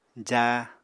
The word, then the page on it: jáa: apatani